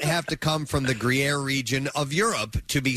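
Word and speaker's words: Gruyère: I uh, it's spelled G R U Y E R E, and the woman had told me just think green air. That's the pronunciation. Gruyere, green air.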